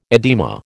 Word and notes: edema: American English

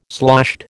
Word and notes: sloshed: American English

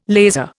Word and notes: laser: Indian

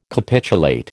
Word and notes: capitulate: American English

